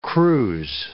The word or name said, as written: cruise